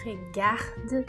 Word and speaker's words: regarde: Porque muitos alunos iniciantes têm a tendência em falar je ou je por exemplo. Pronunciar o som do E, porque quando você for olhar, je regarde, o regarde é escrito com E no final.